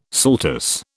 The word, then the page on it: saltus: British English